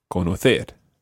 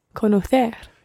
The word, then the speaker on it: conocer: All the infinitives always are stressed on that last syllable. So, mm, so it's saber, conocer. – Saber, conocer.